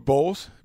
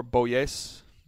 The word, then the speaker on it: Bolles: How are you pronouncing that last name? – I would go with Bowles. B-O-L-L-E-S. – Or Boyes. – We're not going to go there with the double L's.